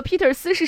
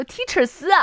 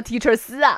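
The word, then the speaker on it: Teacher斯: 我孩子这两天一直在问我说 Peter 斯是什么，我还纳闷，今天我突然想到会不会是 Teacher 斯。对呀，我说的是 Teacher 发音这么不标准吗？我天天说的是 Peter 斯吗？我说 Teacher 斯啊，这个环节的节目就叫 Teacher 斯啊。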